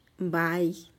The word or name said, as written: bai